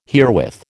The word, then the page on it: herewith: American English